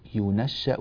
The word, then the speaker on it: ينشأ: فنحن في بلاد المشرق الإسلامي نقرأ برواية حفص عن عاصم، فنعتمد ذلك ونقرأ به، فنقول أو من ينشأ، ينشأ بضم الياء فتح النون تشديد الشين، ينشأ. طيب تلاحظون أيضا في الكلمة نفسها ينشأ أن الهمزة رسمت على واو، هذا لا أثر له في النطق، يعني لما نصل ينشأ في الحلية، وإذا وقفنا نقول ينشأ، ولا أثر لهذه الواو في القراءة، لا أثر لها في القراءة.